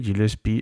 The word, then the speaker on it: Gillespie: Gillespie, a quien me he dado cuenta pronuncio a la francesa, así que diré Gillespie a partir de ahora,